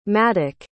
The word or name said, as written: Maddock